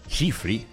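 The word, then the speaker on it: Tschiffely: Aguijoneado por la curiosidad, acudí a internet para investigar sobre esta combinación de nombre extraño, Aimé, y apellido impronunciable porque empieza con TS. Tshechifli, que así se pronuncia, sin la T al principio,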